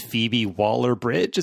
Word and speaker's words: Phoebe Waller-Bridge: It's written by the lady that did Fleabag, and I want to say her name is Phoebe Waller-Bridge. Is that right? – Correct.